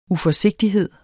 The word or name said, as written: uforsigtighed